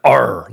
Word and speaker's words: AARRR: And the reason why it's called the pirate metrics model is because it's a five-part acronym with A-A-R-R-R. So when you pronounce it, it sounds like R, like a pirate.